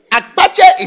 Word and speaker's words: Akpatse: Akpatse is spelled A K P A T S E.